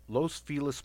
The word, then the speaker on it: Los Feliz: But, yeah, check out the Ghost Adventures, Los Feliz Murder Houses.